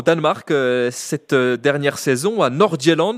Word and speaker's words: Nordjylland: Je ne sais pas si je prononce bien, peut-être que vous êtes meilleur que moi en danois. Surpris ou pas spécialement de voir le Danemark à ce niveau-là du tournoi? – Alors, le FC Nordjylland, non, la prononciation était pas mal du tout.